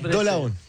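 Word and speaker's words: Dolavon: Y muy bien, pronunciaste bien el nombre de Dolabón, que no es tan fácil. – Claro, porque sí, es Dolabón.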